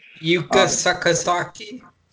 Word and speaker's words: Yuka Sakazaki: Yuka Sakazaki.